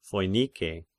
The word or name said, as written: Phoinike